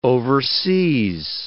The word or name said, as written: overseas